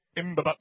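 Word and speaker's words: Mbabane: Later moved to the capital of, how do you say that? Yep, okay. Mbaba. I knew I was going to mess that one up. There's no way to work that out.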